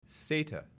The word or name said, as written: SATA